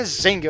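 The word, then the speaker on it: Zheng: Ela passou ali dos próximos 6 anos ao lado do capitão pirata Sheng primeiro, que era um cara muito foda. Ou Zeng, né? A pronúncia é meio difícil do chinês, né? A pronúncia certa é Zeng.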